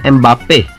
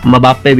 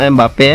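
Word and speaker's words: Mbappé: और फ्रांस की अगर मैं बात करूँ तो फ्रांस में एक प्लेयर है जिसका नाम है एम्बाप्पे, मबाप्पे भी कहते हैं उसे लोग कुछ, मतलब जिनको प्रोनाउंस करना नहीं आता ना, एक तो नाम ही ऐसा है एम्बाप्पे BAPPE, तो ऐसे ही बोलेंगे ना मबाप्पे, तो वैसे इसका नाम एम्बाप्पे है।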